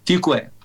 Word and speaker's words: Fuqua: Is his surname pronounced Fuqua? – Fuqua. – Okay. So Fuqua, yeah. – That's not Fuqua. – Fuqua would be the French pronunciation, I guess.